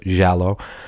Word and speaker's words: giallo: And, you know, anybody that follows like Italian horror films, like giallo, that's how they pronounce it, giallo, giallo,